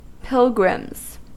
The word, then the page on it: pilgrims: American English